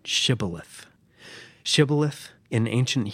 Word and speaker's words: shibboleth: But the Gileadites pronounced it with a sh sound, like shibboleth, and the Ephraimites pronounced it with a s, sibboleth.